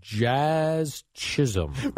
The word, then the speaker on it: Jazz Chisholm: And now batting Jazz Chisholm.